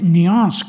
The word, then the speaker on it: nuance: Matiz se dice nuance con la, con la pronunciación francesa